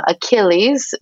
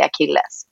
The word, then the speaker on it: Achilles: Så vi har bara fortsatt på amerikanska, eller liksom, nu är det här ett grekiskt namn, men uttalet på engelska. Så vi säger hemma Achilles, men på svenska säger vi Achilles.